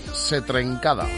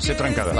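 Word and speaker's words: ce trencada: Manel de Tarrasa, buen día, se pronuncia Setrencada. – La sé trencada.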